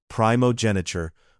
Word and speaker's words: primogeniture: pronounced primogeniture, where the firstborn bore representative responsibility.